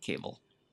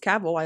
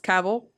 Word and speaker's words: Cavill: Cable. – It's Cavill. I looked it up. He has a video on Google that said it's Cavill, rhymes with travel.